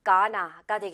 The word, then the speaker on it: gonna: going to, going to, going to, going to, gonna. 이렇게 된 거예요. 그래서 이거 발음은 그런데 고나가 아니고요. 이건 또 고나라고 알고 있는 친구들 많이 봤어요. 이거는 가나가 되겠습니다. 가나.